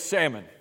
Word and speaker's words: Salmon: How do you say it? Okay, just checking. The elders say it's salmon.